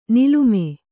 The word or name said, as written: ನೀಲಿಮೆ